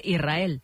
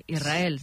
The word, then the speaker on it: Israel: Y la S con la R es difícil de pronunciar. La gente dice Israel, que suene la S. Israel, sí, es difícil de pronunciar.